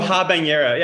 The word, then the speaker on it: habanero: So the silk chili, the carbon ca- habanero chili. Am I saying that right? – I don't um, know. – habanero habanero chili. – I oh, habanero.